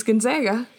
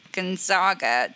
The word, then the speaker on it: Gonzaga: So I'm like, so where is the, how do you go from Gonzaga to Zags? Like, you're not saying Zogs. So I just don't, I'm like, if you know Zags, then like, how are you saying this incorrectly?